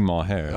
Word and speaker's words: Maher: How do I say your last name? – It's either if you want to say it in Parsi, Maher. – I was trying to be English.